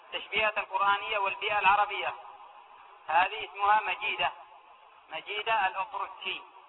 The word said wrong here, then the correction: الأطرقجي: هكذا ينطقوا هذا الأطرق. ثلاث نقاط. الأطرقجي، مجيدة الأطرقجي.